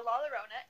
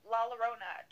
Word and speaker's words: La Llorona: Remember last time I said it's like Corona with L's. And there's two L's in LaLorona. It's like La and then LLona.